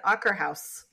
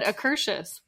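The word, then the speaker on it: Akershus: I think I've said Akerhouse for all these years. – And we have always said Akershush, which is absolutely wrong.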